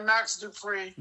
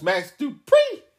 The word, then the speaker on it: Max Dupree: They changed his name to Max Dupree. – No, you're saying it wrong. It's Max Dupree.